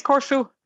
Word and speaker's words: Corfu: which I may have I may have said that wrong. I'll have her clarify yeah, that when she fine. gets on. – Yeah, what what was it? It is Corfu.